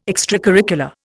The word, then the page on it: extracurricular: Indian